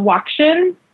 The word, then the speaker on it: WOCSHN: And that was really the birthplace of WOCSHN, which is how we pronounce the acronym.